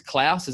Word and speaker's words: Klaus: I love, it's Klaus, is that how we're pronouncing it officially? – Yes.